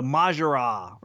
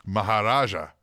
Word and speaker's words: Maharaja: the Majara, right? Is that how you say it? – Maharaja.